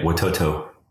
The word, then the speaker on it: Watoto: Is it Watoto, or how do you say it? – You got it right, Watoto.